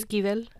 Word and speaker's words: Esquivel: And then the next one, Like Water for Chocolate by Laura, how do you say it? – Angie? Esquivel. – Yeah, there we go. Esquivel.